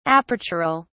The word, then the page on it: apertural: British English